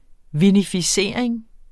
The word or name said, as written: vinificering